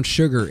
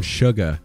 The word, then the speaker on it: Shugga: No, it's not brown sugar ale. It's brown Shugga. – Oh, ale. – Excuse me. – You got to say it right. – I know. I'm sorry. – It literally is Shugga. – It is. – There's no R in that word.